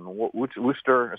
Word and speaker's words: Worcester: They went into county conventions in these western counties, in Bristol, in Middlesex, in Worcester, is how you pronounce it.